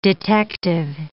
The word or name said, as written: detective